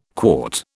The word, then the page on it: quart: British English